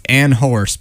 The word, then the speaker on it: Anhorse: It's Anhorse, but I don't think they say the H is there, so I guess it's just Anhorse.